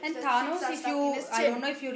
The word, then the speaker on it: Thanos: What is Thanos pronounced? Thanos.